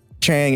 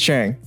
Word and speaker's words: Chheang: Now, I don't have a middle name, so my whole name is Vila Chheang. Chang is the English pronunciation for the Cambodian actual pronunciation of Chheang. Two H's, silent E. So. – Yeah. I did not know it was Chang. – It's Chang, yeah.